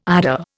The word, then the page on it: IDA: Indian